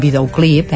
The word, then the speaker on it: videoclip: Hi ha alguna cosa de lèxic, com la camilla, que seria la llitera, o vigilem aquesta pronúncia del videoclip, és un videoclip, eh? És una paraula que l'accent estaria... En, seria una, una aguda.